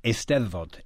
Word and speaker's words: Eisteddfod: Am I saying that right? – You're close. Eisteddfod. – Eisteddfod. I wasn't that close. – Well, everyone says it wrong except for Welsh speakers, so you were good.